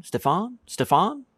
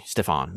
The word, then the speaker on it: Stéphane: S-T-E, stay, dash, P-H-A-W-N, Stéphane? I mean, that's not how it's spelled, but maybe that's how it might help you pronounce it.